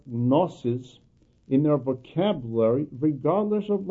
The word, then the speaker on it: gnosis: Gnosis in the Greek language means knowledge, and I am purposely pronouncing it as a gnosis. Actually, in the English language, the G is not pronounced, so it would be gnosis.